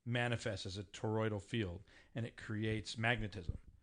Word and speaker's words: toroidal: It is a coherent resonance in a toroidal field. God damn it. – Spell it. – T-O-R-O-I-D-A-L.